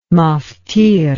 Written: maftir

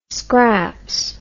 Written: scraps

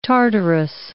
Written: Tartarus

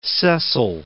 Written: Cecil

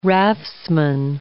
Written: raftsman

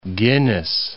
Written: Guinness

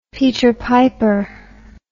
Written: peter piper

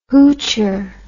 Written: hoocher

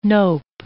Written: nope

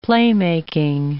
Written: playmaking

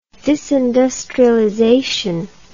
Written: disindustrialization

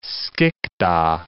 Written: Skikda

